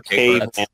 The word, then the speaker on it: kei: Chris, did you say something about K cars? No, I didn't. I'm not even familiar with them. – Gotcha. – The K, it's called, it's spelled K-E-I.